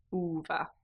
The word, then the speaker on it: uva: And that will remind you that the first sound for grapes is ooh, uva.